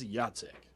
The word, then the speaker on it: Jacek: I'm going to keep pronouncing it in Polish. It's Jacek.